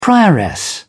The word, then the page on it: prioress: British English